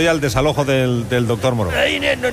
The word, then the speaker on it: Moreau: Se pronuncia Moreau. ¡Doctor Moreau! – Por favor. – Un poquito de calma, por favor. Un poco de calma. – Desalojen al doctor Moreau, por favor.